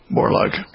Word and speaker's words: Borlaug: Am I pronouncing it right? – That's right, yeah, Borlaug.